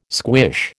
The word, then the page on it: squish: American English